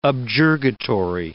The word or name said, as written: objurgatory